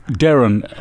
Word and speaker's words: Darren: Ja, hij is van geboorte Engelsman, dus je moet het officieel ook Darren, als Darren uitspreken.